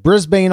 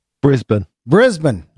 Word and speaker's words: Brisbane: I mean, if I say a word, we were just talking before the recording about, I mispronounced, I said Brisbane, Australia, and it's not Brisbane. How is it? – Brisbane.